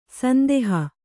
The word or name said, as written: ಸಂದೋಹ